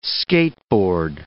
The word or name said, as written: skateboard